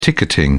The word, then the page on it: ticketing: British English